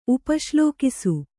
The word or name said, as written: ಉಪಶ್ಲೋಕಿಸು